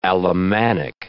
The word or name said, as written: Alemannic